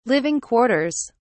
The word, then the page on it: living quarters: British English